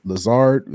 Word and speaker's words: Lazard: The Green Bay Packers are fined $300,000 for violations of the NFL and NFLPA protocols, while Aaron Rodgers and Alan Lazard, is that how you spell his name?